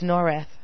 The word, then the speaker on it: Norrath: I spell Narayath. – N-O-R-R-A-T-H. – Oh, so we say it wrong. It's Norath.